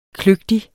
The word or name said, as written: kløgtig